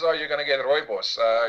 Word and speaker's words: rooibos: Rooibos tea, what you might call red bush. – Rubius. – Because it's too difficult to pronounce in the English language. It's actually referred to as rooibos.